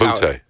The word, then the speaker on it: Boutte: I think it's pronounced Booty. I do. I think that's Boutte. – Boutte. – I think so. – Okay, Boutte. Kayshawn Boutte.